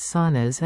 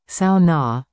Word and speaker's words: sauna: There was much feedback to this story with readers telling of their Backroads saunas and what these abodes mean to their lifestyle. And a reminder at the end of the story. Remember, it is the Finnish pronunciation, sauna, not sauna, on the back roads.